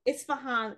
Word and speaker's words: Isfahan: In this case control study, newly diagnosed RA patients who visited a rheumatology clinic in Isfahan, is that how it's pronounced? – Isfahan, Iran, were enrolled.